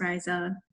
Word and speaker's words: Raiza: And, I usually just say, it's just Raiza, and I'll correct the person until they get it right.